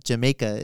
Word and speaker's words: Jamaica: So, to avoid being called twits, Jamaica is the correct pronunciation.